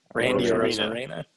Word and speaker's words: Arozarena: Yeah, and he was right behind Orozina. I can't even see his name. Orozina. Is that how you say it? Orozarena. Randy Arozarena.